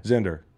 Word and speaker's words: Zender: The crux of the campaign is that it rhymes with Tinder. – Yes. – Okay. – Zender.